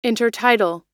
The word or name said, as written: intertidal